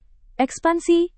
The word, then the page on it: ekspansi: indonesian